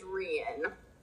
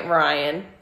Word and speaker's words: Rian: My favorite character is Rian. And so that's like my love. – We have been pronouncing it wrong. We've been pronouncing it Ryan.